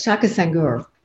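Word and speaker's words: Shaka Senghor: Shaq Sangura, did I get his name right? He knows that. He teases me because I'm always getting his name wrong. But Shaka Sangura, he wrote an incredible book about writing my wrongs, I think his book is, about writing his way out of prison, really.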